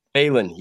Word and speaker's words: Phalen: I keep wanting to call you Phalen, but it's Phalen, correct? – Phalen. Yeah.